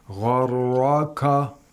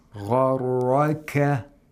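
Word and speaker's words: Gharraka (غَرَّكَ): In this word, we have two letters that are pronounced with thickness, with tafkhim. Ghain and ra. Gharraka. The kaf does not have tafkhim. – Right. – So you have to be careful that you don't pronounce the kaf with tafkhim. Gharraka is incorrect. Gharraka.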